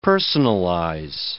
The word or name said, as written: personalize